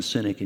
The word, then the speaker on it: Sinek: There's a guy by the name of Simon Sinek, and the name is spelled S-I-N-E-K, but I think it's pronounced Sinek, and he's got some videos out there that have just gone viral, just gone crazy, and he writes especially about millennials.